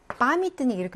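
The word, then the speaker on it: 파미트니크 (памятник): 발음에서요. 파미트니크 이렇게 왔으니까 야의 강세가 없어 이 발음 난다는 게 중요하고 여기는 뿌시킨 광장이잖아요.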